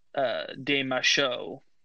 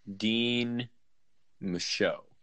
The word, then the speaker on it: Guillaume de Machaut: And that's definitely Guillaume de Machaut. He's kind of the master of that time period. – Pronounce it Guillaume? – De Machaut. – Dean Machaut. – Not Dean, D-E.